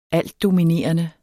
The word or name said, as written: altdominerende